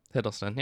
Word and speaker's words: Hiddleston: Tom Middleston. I always say it wrong. Hiddleston, yeah.